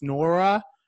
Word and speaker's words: Nwora: Nwora.